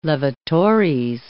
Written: levatores

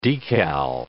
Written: decal

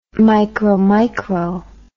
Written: micromicro

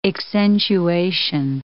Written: accentuation

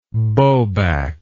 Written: bowback

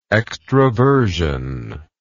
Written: extravertively

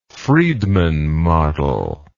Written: Friedmann model

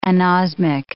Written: anosmic

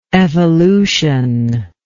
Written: evolutionally

